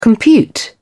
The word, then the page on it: compute: British English